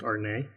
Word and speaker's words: Arnet: Its last name is A-R-N-E-T, but it's pronounced Arnett.